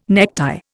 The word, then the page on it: necktie: Indian